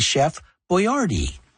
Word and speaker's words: Boyardee: But when you listen to this very first commercial for the brand, you'll realize the correct pronunciation was actually Chef Boyardee.